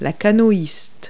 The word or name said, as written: canoeist